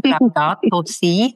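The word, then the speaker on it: Laudato si': Also ich habe verstanden, obwohl ich es falsch ausgesprochen habe, Laudato Si heißt es, betont ja den Zusammenhang zwischen den ökologischen und sozialen Fragen.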